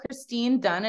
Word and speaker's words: Kristine Dunn: Yeah, I'm on social media, Kristine Dunn, and it's Kristine with a K. Instagram is Get It Done. My last name is spelled D-U-N-N.